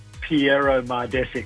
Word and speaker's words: Pjero Mardesic: How do you actually pronounce it? – Pjero Mardesic. So you're pretty close.